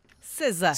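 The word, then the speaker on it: SZA: Au numéro 7, comment est-ce qu'on prononce ça? César? – César. – César. Ouais.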